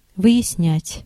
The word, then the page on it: fathom: /ˈfæð.əm/